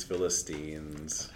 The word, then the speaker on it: Philistines: Should we drag a little bit about the way he keeps mispronouncing Philistines practicing? – Philistines. – How did we tell you to remember it? – Christine.